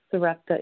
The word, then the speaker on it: Sarepta: it is Sarepta. It's spelled S-A-R-E-P-T-A.